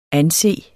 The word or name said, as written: anset